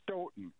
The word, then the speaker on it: Stoughton: You actually, the second time you said it right, Stoughton. They pronounce it Stoughton.